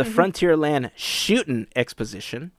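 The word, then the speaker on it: Frontierland Shootin' Exposition: Due to the success of the Frontier Shooting Exposition, there's no G, it's an apostrophe, gotta say it right, of the Frontierland Shooting Exposition,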